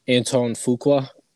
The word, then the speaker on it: Antoine Fuqua: Antoine Fuqua, the director.